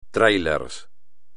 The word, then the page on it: trailer: espagnol